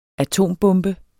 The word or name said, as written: atombombe